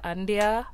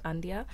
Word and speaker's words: Andia: I'm Gabrielle Andia. I think, let me just say my artist name is Andia, just Andia.